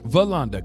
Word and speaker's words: Veronda: No, it's like Yolanda, but it's Veronda. – Veronda, God bless you.